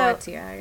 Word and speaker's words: Goetia: Goetia? – Yeah. – So Goetia, yeah. – Goetia? Yeah, okay. Whew. I was like sweating on whether or not I was going to be saying that right.